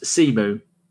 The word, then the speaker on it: Symeou: Harry Symeou is pronounced Simu, like I thought.